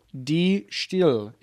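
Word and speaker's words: De Stijl: So it is pronounced, this is the White Stripes second album, Die Stille. It's Dutch.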